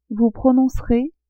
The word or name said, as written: vous prononcerez